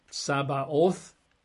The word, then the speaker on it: Tsabaoth: And the Hebrew word is Sabaoth. In English, we'd spell that T-S-A-B-A-O-T-H. Sabaoth, T-S-A-B-A-O-T-H, Sabaoth.